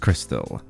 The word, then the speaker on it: Krxstxl: Now guys, this is spelt K-R-X-S-T-X-L. She wants to name her daughter K-R-X-S-T-X-L. Confused, the name is pronounced Crystal.